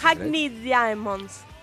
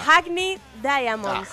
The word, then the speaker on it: Hackney Diamonds: Hackney Diamonds. – ¿Eh? – Lo pronuncié para el ojete. – Bueno, vuelve a intentarlo. – Mira, Hackney Diamonds. Ah, Hackney, ah, ok, ahora sí.